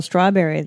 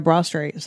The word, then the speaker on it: Strawberry: It's like that thing we saw in Daryl Strawberry the other day. – Mm-hmm. – Like now he's ministering. Daryl Brawstrey. Sorry, Brawstrey. – I mispronounced that.